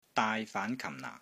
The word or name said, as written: DAAI6 FAAN2 KAM4 NAA4